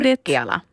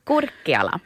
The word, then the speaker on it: Kurkiala: Kurkiala. – Det är ett U där. – Mm. Då blir det där finska uttalet. – Ja. – Kurkiala.